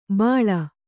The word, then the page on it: ಬಾಳ: kannada